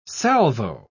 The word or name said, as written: salvo